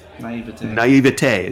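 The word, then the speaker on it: naïveté: Naviette. No, how do you pronounce that? – Naivete. – Yeah, there you go.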